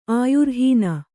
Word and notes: ಆಯುರ್ಹೀನೆ: Kannada

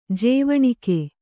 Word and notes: ಜವನಿಕೆ: javanike

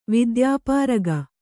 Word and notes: ವಿದ್ಯಾಪಾರಗೆ: vidyāpārage

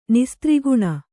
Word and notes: ನಿಸ್ತ್ರಿಗುಣ: nistriguṇa

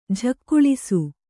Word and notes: ಝಕ್ಕುಳಿಸು: jhakkuḷisu

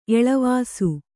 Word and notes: ಎಳವಾಸು: eḷavāsu

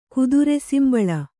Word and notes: ಕುದುರೆಸಿಂಬಳ: kudure simbaḷa

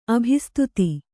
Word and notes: ಅಭಿಸ್ತುತಿ: abhistuti

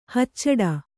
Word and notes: ಹಚ್ಚಿಡು: hacciḍu

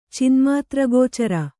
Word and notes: ಚಿನ್ಮಾತ್ರಗೋಚರ: cinmātra gōcara

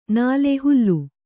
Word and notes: ನಾಲೆ: kannada

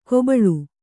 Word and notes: ಕೊಬಳು: kobaḷu

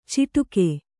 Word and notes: ಚಿಟುಕೆ: ciṭuke

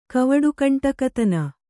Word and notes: ಕವಡುಗಂಟಕತನ: kannada

